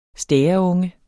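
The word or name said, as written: STÆREUNGE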